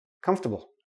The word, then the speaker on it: comfortable: Comfortable. Comfortable. Comfortable. Okay? We don't pronounce the or, comfortable. Nope, don't do that.